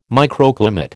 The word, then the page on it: microclimate: American English